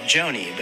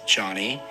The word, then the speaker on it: Jony: For example, my name is Johnny, spelled J-O-N-Y. And it's normal for someone to pronounce my name like Joni.